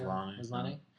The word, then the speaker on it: Maslany: Also, Orphan Black star Tatiana Maslany, I believe is how you say it. – Maslany.